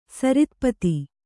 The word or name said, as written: ಸರಿತ್ಪತಿ